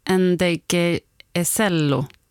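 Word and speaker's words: Ndegeocello: Ngeg-Joe. – Michelle. – Michelle Ngeg-Joe. – Nej, Ngeg-Ocello. Ngeg-Ocello, ja. Kanske.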